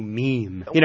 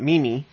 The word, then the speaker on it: meme: And we should spell it M-E-M-E. – M-E, yeah. – So it's not meme-y, it's a no. – meme.